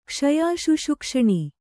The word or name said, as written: ಕ್ಷಯಾಶುಶುಕ್ಷಣಿ